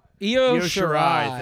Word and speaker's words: Io Shirai: NXT Women's Championship, Shayna Baszler versus, I don't even know how to pronounce this, Io Shirai? – Io Shirai.